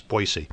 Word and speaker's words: Boise: Boise. And by the way, some people pronounce it with a Z in the middle. And oh, no, it's Boise.